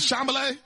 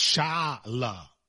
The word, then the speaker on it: Chalamet: Well, now I know it's Chalamet. I know it's like the whole. – Okay, so yeah, well, that's three syllables, but let's put them all together into a word. – Chalamet.